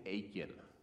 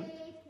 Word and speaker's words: Achan: But there was a guy named Achan. Rhymes with bacon. Rhymes with bacon. – Achan. – Say Achan. – Achan. – Not bacon, Achan.